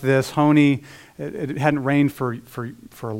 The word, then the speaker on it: Honi: It takes a real historical figure from the first century BC, a man named Honi, H-O-N-I, I think that's how you say it, Honi.